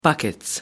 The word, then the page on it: buckets: British English